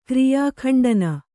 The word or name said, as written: ಕ್ರಿಯಾಖಂಡನ